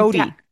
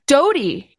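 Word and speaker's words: Doughty: How do you say her name? Yeah, Doty. – Doty by Caitlin Doty.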